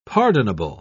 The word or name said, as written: pardonable